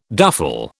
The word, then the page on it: duffle: British English